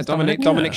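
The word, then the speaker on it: Dominik: I'd imagine there'll be some Dominic, did I say that right? – Yeah, Dominic shenanigans.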